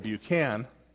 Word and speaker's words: Buchan: I was introduced today as Otis Buchanan. In fact, my last name only has one A-N, so I pronounce it Buchan.